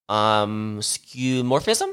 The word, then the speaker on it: skeuomorphism: And we're kind of moving away from skeuomorphism.